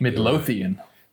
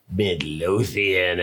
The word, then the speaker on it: Midlothian: Midlothian. – Midlothian, Illinois.